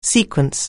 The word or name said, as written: sequence